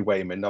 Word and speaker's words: Waymond: It is actually Waymond. I'm not doing a, I'm not mispronouncing it. It's with a W.